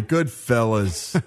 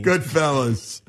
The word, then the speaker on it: Goodfellas: Yeah, fellows? – Sorry, Goodfellas. I'm from Jersey. – It's okay off the air, but on the air, you got to say it correctly. – Goodfellas.